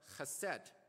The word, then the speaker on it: chesed: And in Hebrew, is pronounced as chesed. Chesed.